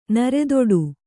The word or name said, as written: ನರೆದೊಡು